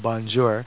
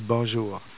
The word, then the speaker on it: bonjour: And the more formal way, of course, would be bonjour, and everybody's heard, bonjour, the kind of anglicized way to say it, but the proper pronunciation would be bonjour.